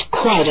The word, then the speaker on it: quite: Q-U-I-T-E. It is one syllable. Quite. Don't confuse this word with quiet, Q-U-I-E-T, which is two syllables and means silent. In the next exercise, figure 6, you will hear a word on the tape.